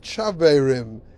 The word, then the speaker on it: Chaverim: I said, "I don't know if you can pronounce it, but you can pronounce it Chaverim.